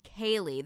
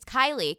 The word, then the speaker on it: Kylie: So I'm there, and each time a nurse walked into my room, they're expecting a white girl named Kaylee. They wrote my name wrong. – Kaylee. – And I'm like, it's Kylie. Kylie Russell.